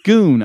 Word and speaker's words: Goon: G-O-O-N? – Yeah, Groan. – G-O-O-N is Goon. – Yeah, Goon. I'm sorry. Goon. – You said Groon. – Yeah. Goon. I'm sorry. – There's not an R in that word.